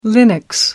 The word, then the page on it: linux: American English